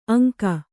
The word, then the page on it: ಅಂಕ: aŋka